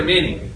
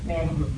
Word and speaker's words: Many: Is it Manny or Minnie? – Manny. – Are you sure? See, that's an example right there. M-A-N-Y. How would you say that? – Northwestern. – No, not if you're from Louisiana, you say Manny, not Minnie.